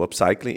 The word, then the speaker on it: upcycling: que é o tal do upcycling. É assim que pronuncia? Correto? Upcycling, ok.